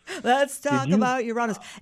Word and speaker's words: Uranus: Every 84 years, there is a planet that's called Uranus, and it is, or as some would say, Uranus. – Oh, please. Yes, Uranus. Uranus is another pronunciation.